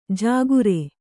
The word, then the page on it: ಜಾಗುರೆ: jāgure